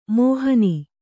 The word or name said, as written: ಮೋಹನಿ